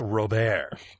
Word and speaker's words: Robert: Which consists of an adorable nephew, an adorable sister-in-law, and a not-at-all-adorable brother called Robert. Only since they're French and not English, it's actually pronounced Robert.